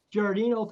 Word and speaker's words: Giardino: Am I saying that right, Sheriff Giordino? – Giordino, thanks for having me, Bill.